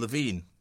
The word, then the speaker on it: Levine: material, uh, it, has been produced by uh, again another legend uh, of the Northern Soul scene, uh, Ian Levine. Uh, is it Levine or is it Levine? How do Levine. you pronounce Levine? Levine. I got it right the first Levine.